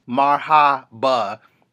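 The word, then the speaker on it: marhaba (مرحبا): Mahabo, though, folks. – What you just say, man? – Mahabo, though. Mahabo, though. Folks, what language is that? – I'm pronouncing marhaba, which is hello in Arabic.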